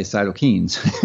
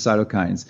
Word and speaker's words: cytokines: And I can tell you the ways it does this through a mechanism called cytokines, or as Ben would say, cytokines. But the correct term, the correct pronunciation is cytokines.